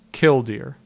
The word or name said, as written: Kildeer